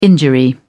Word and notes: injury: British English

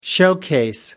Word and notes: showcase: American English

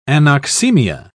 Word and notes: anoxaemia: British English

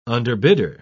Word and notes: underbidder: British English

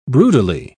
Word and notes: broodily: British English

